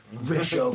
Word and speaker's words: Ra's al Ghul: Ra's al Ghul. – That's the correct way to pronounce it, though.